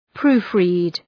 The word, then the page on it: proofread: ‘pru:f,ri:d